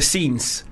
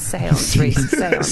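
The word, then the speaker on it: séance: Did you do a scenes? – Yeah, we did a scenes. – A scenes. – Seance.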